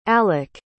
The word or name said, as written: aleck